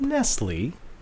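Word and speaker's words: Nestlé: Is it Nestle or Nestle? – It's Nestle. – But why is the accent there? – To trip you up. – Because French people would pronounce that Nestle.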